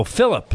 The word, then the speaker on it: Philip: P-H-I-L as in Laura. – Yeah. – L as in Laura. I-P. – Oh, Philip. I thought you said Philly. – No, that's your Philip. You're focused on Aaron Nola and the boys. – Okay, Philip.